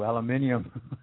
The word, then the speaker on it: aluminium: Well, the detoxing from chemtrails, basically you've got some, even though aluminium, I like to pronounce it the way the British do, aluminium is a light metal.